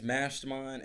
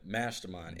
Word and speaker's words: MVSTERMIND: My name is Mastermind, and it's spelled with a V. So the A is an upside down V. Ding. The A is an upside down arch. But Mastermind, MVSTERMIND.